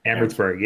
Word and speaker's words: Amherstburg: I know you've been an economic and policy analyst, and my apologies if I mispronounce this, but it's Amherstburg? – Amherstburg, yes.